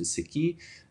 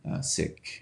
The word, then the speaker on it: Sikh: Sikh, sometimes pronounced Sikh.